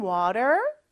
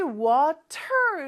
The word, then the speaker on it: water: 근데 미국이나 북미권에서는 이 T가 모음 소리 사이에 껴있을 때는 리을처럼 발음이 돼요. So 왜 R 발음할 때는 혀가 어디에도 닿으면 안 된다고 했죠? 얘는 입천장에 살짝 닿았다가 떨어지는 소리예요. Water, 이렇게 혀를 굴리는 것처럼 그거를 살짝 한번 해주면 돼요.